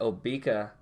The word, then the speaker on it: Obeka: But I prefer to pronounce it Obeka specifically because it rhymes with Topeka.